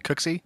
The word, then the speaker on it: Cooksey: Cooksey.